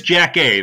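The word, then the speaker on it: Jackée: Is that how you pronounce it? Jackie? – He is Jack A, the lady from 227, yes.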